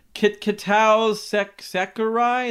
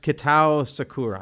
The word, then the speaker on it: Kitao Sakurai: Bad Trip, directed by Kitao Sakurai. Is that what he said? I said that totally wrong. – I think it's pronounced Kitao Sakurai.